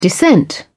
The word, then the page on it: descent: British English